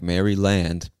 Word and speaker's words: Maryland: I know it looks like Maryland, but we just pronounce it Maryland.